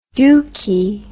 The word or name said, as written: dookie